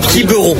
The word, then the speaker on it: Quiberon: Alors, Les Trois Fromages, c'est un groupe de Quiberon, je le dis bien. – Quiberon.